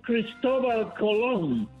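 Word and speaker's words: Cristóbal Colón: His name was Cristobal Colon. That was his real name, the way it's pronounced.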